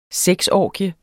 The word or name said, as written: sexorgie